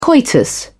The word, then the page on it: coitus: British English